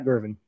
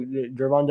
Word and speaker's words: Gervon: So Gervon Dexter, gotta say his name right, not Gervin, Gervon.